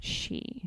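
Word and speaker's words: sidhe: It's Gaelic. So it's S-I-D-H-E. – Okay. – Pronounced she. – Okay. – There's also an Irish word pronounced Dracula, spelled D-R-E-A-C-H hyphen, that's Drac, and Hula being F-H-O-U-L-A, which means bad or tainted blood.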